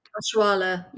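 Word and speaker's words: Oshoala: Like with Nigeria, so many players in that Nigerian team playing international football at some of the highest level, like Oshala, is it? – Oshuala, yeah, for Barcelona.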